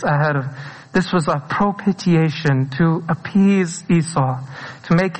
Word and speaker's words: propitiation: And I think both the Catholic and the Pauline Epistle today, there's a very important word that illustrates this concept, and everyone always mispronounces it. It's called propitiation. Propitiation.